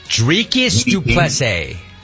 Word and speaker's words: Dricus du Plessis: Dricus du Plessis.